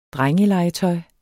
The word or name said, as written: drengelegetøj